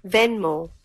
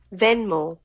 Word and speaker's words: Venmo: Venmo. – There we go. Hold on one more time. – Venmo.